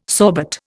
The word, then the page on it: sorbet: Indian